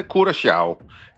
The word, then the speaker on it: Curaçao: Ja, hvis du må tage det helt korrekt, så siger det, hedder Curaçao. Det der C med den lille krølle under, det får sådan en sjav-lyd, men langt de fleste, de udtaler det blot som et SC, så Curaçao.